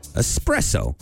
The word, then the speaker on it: espresso: So coming in at number five, espresso. A lot of people say espresso, but there's no X in there, so stop that.